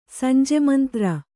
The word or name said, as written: ಸಂಜೆಮಂತ್ರ